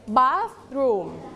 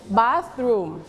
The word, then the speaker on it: bathroom: bathroom don't forget th you have to use your tongue เราต้องใช้ลิ้นเรานะคะแตะไว้ที่หลังฟันบนนะคะแล้วออกเสียง bath okay bathroom not bath bath not su bath ออกให้เป็นเสียงเถอะให้ได้นะคะ bathroom One more time, bathroom.